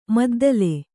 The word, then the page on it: ಮದ್ದಲೆ: kannada